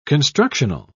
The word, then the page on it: constructional: British English